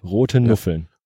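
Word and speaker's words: Rothenuffeln: Also rote mit TH schon geschrieben, aber rote Nuffeln. Roten Uffeln, genau. Das war nicht sehr, Spricht man das roten Uffeln aus? – Ja.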